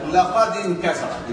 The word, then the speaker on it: انكسر: اذا الالف سقطت في وسط الكلام. لكن لو بدانا نقول انكسر ننطق هذه الالف لو لم يسبقها قول.